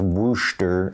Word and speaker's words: Worcester: We were at the Worcester Cup in what appears to be Worcestershire, Massachusetts, but it's Worcester, I believe, is the way they pronounce it.